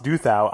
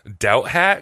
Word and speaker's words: Douthat: I also always pronounce that guy's name Doubt Hat because I think that sounds funny. – I pronounce him as Ross Doubt That because I'm like, that's a bad take, my dude.